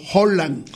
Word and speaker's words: Haaland: De Erling Haaland se pronuncia fonéticamente como Holland. – Hola. – O con H o con J, Holland, con una J suave y aspirada. La doble A del noruego original, Haaland, se pronuncia como una O alargada, mientras que la D al final es muda y muy sutil.